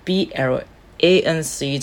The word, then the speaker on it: Blanc: 们会看到一个英文，这个英文是怎么念啊？不是英文，说错，是法文，法文 Blanc de Blanc，Blanc de Blanc，这个意思就是白葡萄品种的白酒，就是我们俗称的白中白。哦，其实这个呃，有常常在品酒的人应该会知道这个 B L。 A N C 这个这个法文发音会念 Blanc，就像我们常常会说的白苏维浓叫 Sauvignon Blanc。